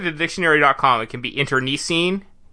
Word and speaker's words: internecine: 90% sure you pronounced that word wrong. – How would you pronounce it? – I don't think there's an I where you pronounced an I. – I had always heard it pronounced with an I. I know there's an E there. – I think it's internecine. – No, you're putting the emphasis on the wrong syllables there. – Well, there's definitely no... There are 2 I's in the word.